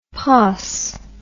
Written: poss

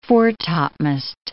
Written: fore-topmast

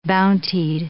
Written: bountied

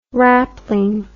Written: wrapling

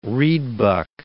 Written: reedbuck

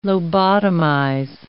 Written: lobotomize

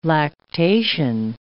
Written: lactation

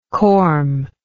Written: cormous

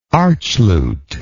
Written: archlute

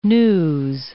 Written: news

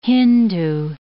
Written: Hindoo

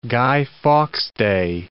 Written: Guy Fawkes Day

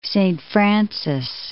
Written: Saint Francis

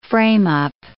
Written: frame-up

